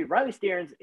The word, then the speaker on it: Riley Stearns: Riley Stearns is, and you did say it right, you know, outside of the fact that you have an accent, but it's not your fault. And we love that accent anyways. But yeah, you said it right.